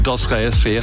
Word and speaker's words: Dostoevsky: and it doesn't include Dostoevsky. – See, well, he certainly pronounced it properly as well.